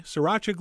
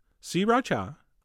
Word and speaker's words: sriracha: And then to our honey, we're basically going to add the same amount of sriracha, which is how I've been told to pronounce it. 3 distinct syllables, sriracha. Although, you know what? That sounds kind of weird. I'm going back to sriracha.